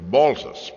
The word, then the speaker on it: Malthus: Now, I know that it's true that in the film treatment he's described as Malthus, but J.M. Keynes, who knew a lot about these things, J.M. Keynes was very emphatic that the pronunciation was Malthus, because it's really a corruption of the words malt house, although I concede that it's a bit more awkward to say Malthusian rather than Malthusian, which we're so used to.